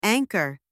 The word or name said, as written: anchor